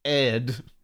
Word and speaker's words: Aedh: So two of them they were brothers and they are spelled A O D H and A E D H, which I pronounced Odd and Ed. – I think it's Odd and Ed. Just Odd and Ed.